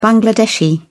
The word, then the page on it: bangladeshi: British English